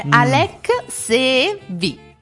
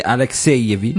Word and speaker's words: Alexeyevich: Non lo so come si scrive. – Alexeyevich. – Quindi Alexeyevich.